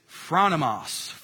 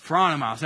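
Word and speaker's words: phronimos (φρόνιμος): That word, be shrewd as serpents, shrewd, that Greek word is phronimos, phronimos. Everybody say that, phronimos.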